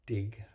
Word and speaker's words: Digh: The story is written by a woman named Patty Digh, I believe her name is spelled, pronounced D-I-G-H.